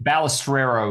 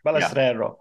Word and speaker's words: Balestrero: Yeah, Ballestrero is the way it's pronounced. – Ballestrero. Yeah. Okay, I should get that right, because it's it's an Italian name.